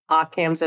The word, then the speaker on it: Occam's: Occam's, am I pronouncing it right? Occam's Advisory. – Yep.